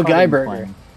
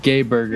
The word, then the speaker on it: Geiberger: Oh, Al Geiberger. Mr. 59. – I think it's pronounced Geiberger.